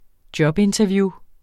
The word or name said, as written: jobinterview